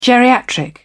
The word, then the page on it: geriatric: British English